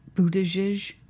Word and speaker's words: Buttigieg: Can we talk about Pence and Buttigieg? Is that how you say it? It is. I believe so.